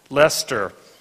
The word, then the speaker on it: Leicester: Leicester in England.